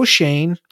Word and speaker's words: Schoen: I will say about the Giants, though, Joe Schoen,